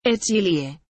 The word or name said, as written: Echlye